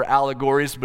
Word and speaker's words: Owlegories: One of those shows is called Owlegories, but it's spelled O W L E G O R I E S, Owlegories.